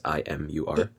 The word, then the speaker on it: IMUR: But the band is called IMUR, spelled like the letters IMUR. – Imur. – Yeah, Imur.